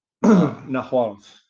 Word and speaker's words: Nahuatl: Now, how do you pronounce that? Nahuas? – Nahuatl. That's old Spanish.